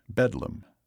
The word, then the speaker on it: Bethlem: He spent most of the rest of his life at the state criminal lunatic asylum in London's Bethlem Royal Hospital, which locals pronounced Bedlam.